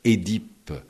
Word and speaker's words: Œdipe: Il insistait sur le fait que l'on devait dire Édipe et Ésophage, et non Œdipe et Ésophage, ce que disent tous les Français.